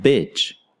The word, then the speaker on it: bitch: The sounds we have here, according to the International Phonetic Alphabet, are E for beach and I for bitch. So, as you can see and hear, there really is a difference when pronouncing beach and bitch.